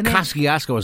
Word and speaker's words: Kosciuszko: Governor Cuomo must have a driver's license because he drove across a new Kosciuszko Bridge. – Exactly. – Or Kosciuszko, as the locals say.